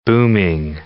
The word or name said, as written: booming